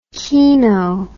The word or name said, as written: keeno